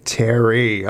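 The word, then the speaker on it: Terri: Terry.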